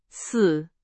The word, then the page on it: 死: Chinese